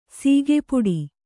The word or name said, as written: ಸಿಕ್ಕಾಪಟ್ಟೆ